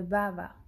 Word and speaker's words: bhava: baba, que se escribe B-H-A-V-A.